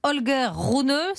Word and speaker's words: Holger Rune: Il s'appelle Holger Rune. C'est bon, c'est la prononciation c'est est bonne. – Exactement.